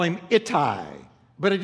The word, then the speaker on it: Ittai: His name is Ittai. Now, it's spelled I-T-T-A-I.